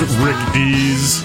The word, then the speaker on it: Rick Dees: When you're saying his name wrong, it's Rick Dees.